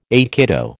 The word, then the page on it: aikido: American English